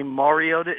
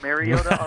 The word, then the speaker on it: Mariota: I am aware that ESPN can't seem to say Mariota instead of Mariota on all of the, ads they run.